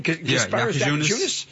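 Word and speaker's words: Jakučionis: How do we say his name? – Jaquizunas.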